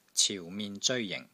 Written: CIU4 MIN6 ZEOI1 JING4

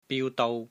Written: biu1 dou1

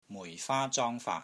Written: MUI4 FAA1 ZONG1 FAAT3

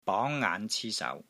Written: BONG2 NGAAN5 CI1 SAU2

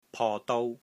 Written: PO3 DOU1

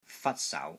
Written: FAK3 SAU2

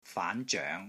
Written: FAAN2 ZOENG2

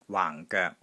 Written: WAANG4 GOEK3

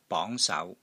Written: BONG2 SAU2

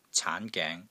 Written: CAAN2 GENG2

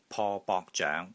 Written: PO3 BOK3 ZOENG2

